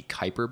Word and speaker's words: Kuiper: Kuiper Belt, if I'm pronouncing that correctly.